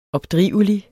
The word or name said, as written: opdrivelig